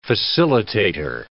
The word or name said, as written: facilitator